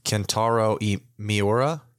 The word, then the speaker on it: Kentaro Miura: Kentaro Miura.